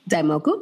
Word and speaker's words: Daimoku: Is it Daimoku? – Daimoku.